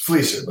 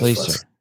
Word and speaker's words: Fleser: Yes, Fleser, but everybody says Fleser.